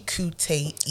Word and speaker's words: Kuteyi: BAMIKUTEYI, and it's actually Kuteyi.